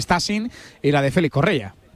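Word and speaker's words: Stassin: Estamos hablando de un delantero, el nombre de Stasin, así se pronuncia, este jugador belga muy jovencito. – Stasin. – Stasin,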